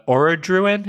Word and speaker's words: Orodruin: And then, you know, the water pours into the magma pool that exists below the Orodruin, is that how you say it? – Yeah.